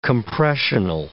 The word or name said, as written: compressional